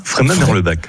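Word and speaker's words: Freyming-Merlebach: J'ai bien dit Fréminges ou Fréminges? – Fréminges, Merlebach. – Merlebach. Ah ben, j'ai bien prononcé.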